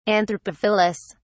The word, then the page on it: anthropophilous: British English